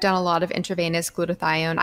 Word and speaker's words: glutathione: I generally do the Myers cocktail, but will also add glutathione.